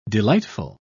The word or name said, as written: delightful